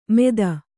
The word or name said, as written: ಮೇದ